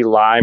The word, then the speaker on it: Ley: Casey Lye, my last name is spelled L-E-Y. Instagram, K-C-W-L-E. Oh, no, Twitter, K-C-W-L-E-Y.